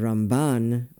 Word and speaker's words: Ramban: And Ramban when mm-hmm. talking about Nachmanides.